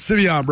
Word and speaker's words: Simeon: He told me last year, it's Simeon, bro. Say it right.